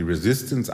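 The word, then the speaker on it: Résistance: Wenn er 35 Jahre alt ist, wird er die sogenannte Resistance, wird französisch ausgesprochen, die Resistance anführen,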